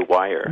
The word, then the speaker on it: Weyer: It's actually Wire. – Wire, yeah. – Yeah, like telephone wire. Yeah. – Okay, it's W-E-Y-E-R, Jonathan Weyer.